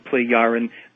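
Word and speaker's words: Plejaren: Yeah, so the J is a Y or an I kind of a sound. Pleiaren.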